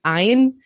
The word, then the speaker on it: Ayn: I would say, want to say Anne, but it's Ayn.